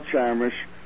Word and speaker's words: Chamish: Write in my name, and that's spelled C-H-A-M-I-S-H. That's how you spell Shamish, and it'll take you to the books, including, as I said, Bye-Bye Gaza.